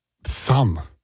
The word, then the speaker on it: Thum: That track, Thumb, is how you say it. T-H-U-M from Violet Grohl.